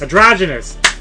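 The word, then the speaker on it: Androgynous: some new stuff from Awigit and Amorphous Andronus, Adronus, Adronus, yes. I can pronounce that. – Adrogenous. – Adrogenous. – Thank you.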